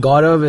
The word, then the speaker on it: Gaurav: Oh, so, I mean, Gaurav is a... I mean, when I was not in India... It's a kind of tough name to pronounce for some people, so they just call me G, you know, Dr. G.